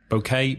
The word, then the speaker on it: bouquet: the bride usually has a bouquet of flowers. Bouquet, B-O-U-Q-U-E-T. Are you going to tell us how you pronounce that in French? – Bouquet. – Bouquet. Bouquet. Sounds much better in French, doesn't it?